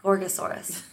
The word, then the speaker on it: Gorgosaurus: Gorgosaurus.